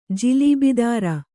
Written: ಜಿಲೀಬಿದಾರ

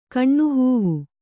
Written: ಕಣ್ಣುಹೂವು

